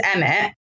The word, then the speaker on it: Emmet: So the word is Emmet. – Emmet. – It's spelled E-M-M-E-T.